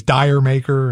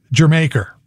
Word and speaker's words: D'yer Mak'er: That tune, I've heard it pronounced so many ways, Dire Maker, and the actual pronunciation, according to Led Zeppelin anyway, is Jermaker. D-Y-E-R-M-A-K-E-R.